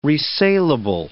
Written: resalable

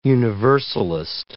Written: universalist

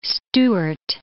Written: Stuart

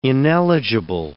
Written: ineligible

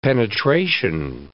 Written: penetration

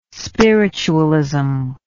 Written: spiritualism

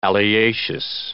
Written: alliaceous

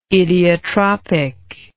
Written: idiotropic

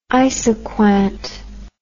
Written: isoquant

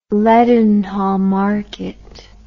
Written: Leadenhall Market

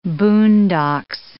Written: boondocks

